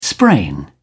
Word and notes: sprain: British English

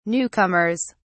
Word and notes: newcomers: British English

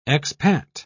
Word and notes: expat: British English

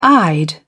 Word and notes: eyed: British English

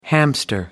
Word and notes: hamster: American English

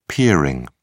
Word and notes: peering: British English

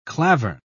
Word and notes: klavern: British English

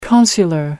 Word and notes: consular: American English

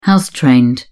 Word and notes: house trained: British English

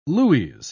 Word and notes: lues: British English

